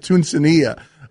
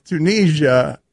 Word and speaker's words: Tunisia: In the local hour, Mike, I pronounced the name of a country wrong. I called it Tunisia. – Tunisia. – It's Tunisia. And I thought you nailed it.